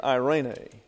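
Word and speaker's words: irene (εἰρήνη): E-I-R-E-N-E. Irene. These are aitas, pronounced like an English long A. This is a diphthong, pronounced like an English long I. So it's irene.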